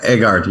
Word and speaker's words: Egard: E-G-A-R-D. Eggard. Am I saying it right? – Yeah. Eggard. Yeah.